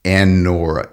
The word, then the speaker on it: Anura: It is Anura, A-N-U-R-A.